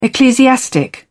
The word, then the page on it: ecclesiastic: British English